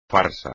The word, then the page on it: farsa: espagnol